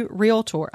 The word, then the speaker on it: realtor: There's not a la in there. – It should be realtor.